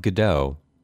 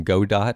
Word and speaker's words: Godot: We've not yet gone on to Phoenix Wright 2 or 3, but this is the theme of Godot, or I called him Godot when I was reading it because he has like a robotic visor on his face, and it just kind of felt right to pronounce it the way that you would pronounce robot, but I guess Godot is probably the correct way to pronounce it, although listeners of Sound of Play in the past will know that I am not a stickler for exactly correct pronunciations.